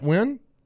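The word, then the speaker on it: Nguyen: Dat Wynn. Is that how you say his name? – Yeah.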